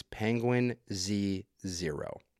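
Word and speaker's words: Penguinz0: The way you say his name is Penguin Z Zero.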